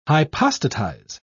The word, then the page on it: hypostatize: British English